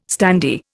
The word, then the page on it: standee: Indian